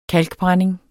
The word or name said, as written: kalkbrænding